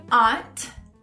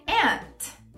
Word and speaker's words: aunt: Bom, primeira palavra que alguns até já conhecem, que tem duas formas de pronunciar, é a palavra tia em inglês. Alguns dizem aunt, repeat with me, aunt. Outros dizem aunt, aunt.